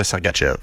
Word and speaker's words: Sergachev: Parce qu'il fallait dire aussi Mihal Sergachev. – Exact. – Mais tout le monde disait Sergachev.